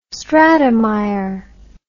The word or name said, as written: stratemeyer